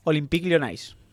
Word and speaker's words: Olympique Lyonnais: Olympique Lyonnais.